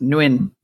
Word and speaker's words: Nguyen: Well, also nobody knows that Vietnamese word, by the way, is pronounced Nguyen.